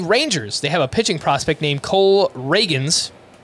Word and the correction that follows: Ragans: or Ragans. It's spelled R A G A N S.